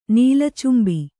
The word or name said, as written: ನೀಲಚುಂಬಿ